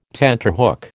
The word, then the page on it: tenterhook: American English